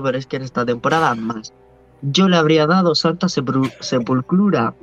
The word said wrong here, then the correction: sepultura: ¿Cómo se dice esa palabra? Sepultura. – Cállate, tonto, cállate, tonto, que no Sepultura.